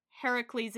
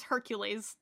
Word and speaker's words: Heracles: Heracles is the correct Greek pronunciation. – Yeah, but they base the name on like Harry as in Heracles. – Yeah, but then they introduce Hercules.